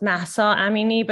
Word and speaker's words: Mahsa Amini: Yes, her name is pronounced Mahsa Amini, but her actual name is Jina Amini.